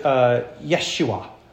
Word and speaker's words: Yeshua: Now, if you were Hebrew, you would pronounce it Yeshua.